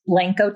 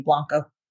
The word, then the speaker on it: Blanco: Well, it's Blanco typically, but everybody calls me Blanco.